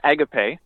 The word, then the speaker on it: agape: Here in North America, it's often pronounced as agape, which most people come to it by. But there's a band called Bear's Den out of the UK that pronounced it agape, and I thought it was just a little bit more melodic.